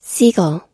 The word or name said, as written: seagull